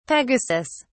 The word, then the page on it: pegasus: British English